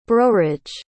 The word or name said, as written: browridge